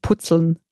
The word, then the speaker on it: Puzzeln: Zeigt sich Dyskalkulie auch beim Puzzeln? oder beim Puzzeln, je nachdem, ob ihr es richtig aussprecht oder so. In Bayern sagt man, also in Bayern sagt man eigentlich Putzeln.